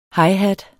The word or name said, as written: highhat